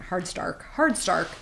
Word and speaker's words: Hardstark: And one of the examples, so it's a true crime podcast by Karen Kilgariff and Georgia Hardstock. How do I say that? Hardstark.